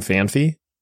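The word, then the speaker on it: Phanpy: where you can get early game Fan Fee, I should say that correctly. – Yeah, that would be right. – And that is solely what the decision is based on, is early game Fan Fee.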